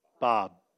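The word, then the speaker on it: Bab: And then you got his forerunner is Bob. It's B-A-B, actually, but yeah, but that's how they pronounce it.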